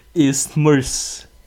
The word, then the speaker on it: isthmus: Isthmus. Isthmus? – There's no R. – Isthmus. – Isthmus. – Isthmus. – Yes. – But it's spelled with a TH.